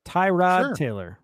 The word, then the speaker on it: Tyrod Taylor: I have, I think I'm pronouncing his name correctly, but Tyrod Taylor. Is that right? – Tyrod Taylor.